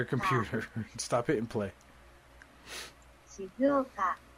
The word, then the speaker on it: Shizuoka: Shizuoka. It's pronounced Shizuoka. – Shizuoka.